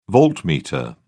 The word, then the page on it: voltmeter: British English